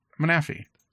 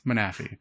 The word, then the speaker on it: Manaphy: Manaphy. You're right, because it's manatee, right? Like, yeah, it makes total sense. Manaphy.